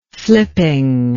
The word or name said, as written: flipping